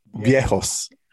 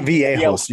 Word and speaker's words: viejos: Viejos. Not viejos. Your accent is on the wrong part.